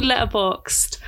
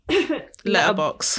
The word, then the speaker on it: Letterboxd: It's called Letterboxd. – Letterboxd. – B-O-X. And then I think it's a D. It's spelt weird, Boxed.